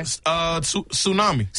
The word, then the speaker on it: tsunami: This has a silent letter in it. – Okay. – Tsunami.